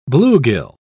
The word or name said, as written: bluegill